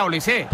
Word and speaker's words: Olise: Es decir, si Mbappé es Mbappé, Dembélé es Dembélé y Benzema es Benzema, pues digo yo que Olis será Olise.